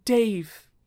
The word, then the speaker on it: Daeve: It's spelled funny. It's like D-A-E V E.